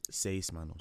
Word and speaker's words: Seis Manos: Isn't it Seis Manos? – Seis Manos, okay.